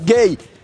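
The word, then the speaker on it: Guéhi: Gay. – Ah, su nombre. – Genera un poco de confusión porque se escribe Güeji. – Ah. – Pero ya explicó él que la pronunciación es gay.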